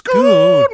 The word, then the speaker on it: Schoon: It's a Dutch name. And so it's pronounced Schoon Man.